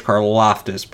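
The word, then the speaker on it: Karlaftis: It's George Karloftis, by the way. Sorry for the mispronunciation there.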